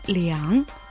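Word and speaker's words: 两: Слово «лиан» читается третьим тоном,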